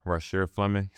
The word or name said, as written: Rasheer Fleming